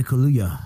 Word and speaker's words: Kaluuya: Daniel Kaluuya. – Kaluuya.